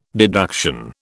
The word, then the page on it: deduction: British English